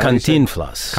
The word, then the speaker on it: Cantinflas: Is that Cantinflas.